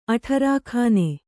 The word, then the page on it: ಅಠಾರಖಾನೆ: kannada